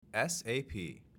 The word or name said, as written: SAP